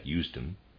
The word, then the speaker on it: Usedom: At one time, Bismarck had a connection with a certain Usedom, and that's, readers aside, that's spelled Usedom, I'm going to pronounce that Usedom, end of readers aside, in Florence and Turin.